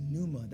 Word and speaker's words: pneuma: The Greek word is pneuma, that's a silent P, all right, it's not a typo, that's a silent P.